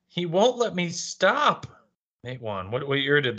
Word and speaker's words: Matewan: I guess it's Matewan. That's how you pronounce it.